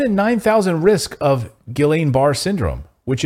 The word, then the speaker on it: Guillain-Barré: I think it's Julian, isn't it? – Julian? Yeah. Guillain-Barré? – No, Julian, because it's... I think. You were saying it right the first time. I've heard it pronounced Guillain-Barré, like you were saying.